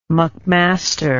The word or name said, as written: mcmaster